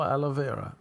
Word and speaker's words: aloe vera: Is that how you, I presume that's not how you pronounce it. Alloy? Aloha? Let's do the old pronunciation. Google. Alloy? No. That's one of those. Alloy. Oh, aloe vera. Alloy, aloe vera.